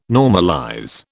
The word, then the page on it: normalize: British English